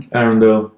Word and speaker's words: Arrindell: Arrindell. But, Arrindell.